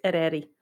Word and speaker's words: Eryri: Oh, it's Welsh, so my closest approximation is Eryri. – There we go. It is spelled E-R-Y-R-I, if you want to type that into Google.